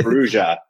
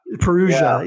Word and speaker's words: Perugia: I don't think it's Perugia. I think it's Perugia. – Perugia, yeah.